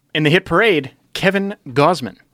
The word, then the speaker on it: Gausman: Gausman almost rhymes with Bozeman. – Sure. – Which is a town in Montana.